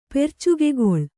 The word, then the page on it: ಪೆರ್ಚುಗೆಗೊಳ್: percugegoḷ